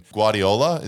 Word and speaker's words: Guardiola: We had a lot of people messaging Guardiola. Is that how you pronounce his name? – Yep.